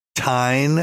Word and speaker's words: Tine: written by a bored housewife in Minnesota named Tine. I guess that's how you pronounce her name, T-I-N-E.